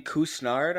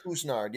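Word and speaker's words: Couisnard: Jermaine Cousinard, I believe it's pronounced. – Cousinard. Yep.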